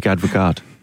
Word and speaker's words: Advocaat: His name is Dick Advocate. – That's great. Beautiful. Oh, yeah. Dick Advocaat is how you'd pronounce that.